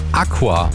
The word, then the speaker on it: ACUA: Das sagt Sänger und Gitarrist von unserer heutigen Heimatkultband Aqua. A-C-U-A in Großbuchstaben geschrieben, aber halt ausgesprochen wie das Latein.